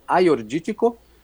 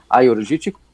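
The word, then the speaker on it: Agiorgitiko: Das brancas, das tintas, a mais plantada é Aiorgítico, que se escreve com Agiorgítico, mas eles não pronunciam o primeiro G, Aiorgítico, e a segunda mais plantada, que eu adoro, é a Xinomavro, Como é que é? – Xinomavro.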